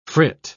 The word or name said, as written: frit